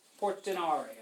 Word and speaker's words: Portinari: Notice those vowels at the end of these Italian names. Technically, if you wanted to pronounce her name in an Italian way, her name is Beatrice Portinari.